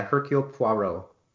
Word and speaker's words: Hercule Poirot: Hercule Poirot.